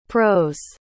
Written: pro's

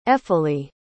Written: eferleah

